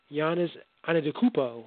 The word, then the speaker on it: Giannis Antetokounmpo: The Greek freak Giannis Antetokounmpo, got his name right.